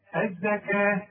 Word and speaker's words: الزكاة: الزكاة كل الزكاة بتاعة القرآن مكتوبة بالواو لكن تنطق بالألف. الزكاة ورسم لك ألفين ثم توليتم.